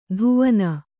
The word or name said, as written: ಧುವನ